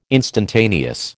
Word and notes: instantaneous: American English

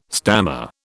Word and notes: stammer: British English

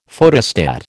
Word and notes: Photostat: American English

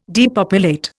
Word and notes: depopulate: Indian

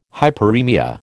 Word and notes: hyperemia: American English

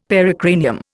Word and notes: pericranium: Indian

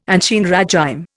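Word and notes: ancien régime: Indian

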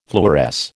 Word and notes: fluoresce: American English

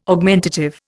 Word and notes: Augmentative: Indian

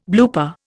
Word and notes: blooper: Indian

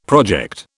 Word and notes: project: British English

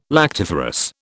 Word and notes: lactiferous: British English

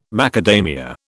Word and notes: macadamia: British English

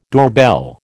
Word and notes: doorbell: American English

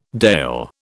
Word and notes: Dàil: British English

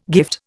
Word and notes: gift: Indian

